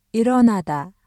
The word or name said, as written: 일어나다